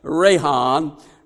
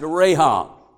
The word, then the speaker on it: racham: It's pronounced Rahan, and it's spelled R-A-C-H-A-M, but it's pronounced Rahan.